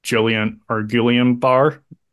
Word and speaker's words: Guillain-Barré: But then right, I think after he finished recording, he came down with Guillain-Barré syndrome. Am I saying that right? – I think so. That's how I'd say it.